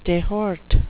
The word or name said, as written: dehort